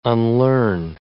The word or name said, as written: unlearn